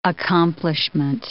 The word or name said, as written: accomplishment